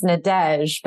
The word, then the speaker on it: Nadège: It's actually pronounced Nadège, but you got Nadège.